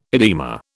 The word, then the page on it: edema: British English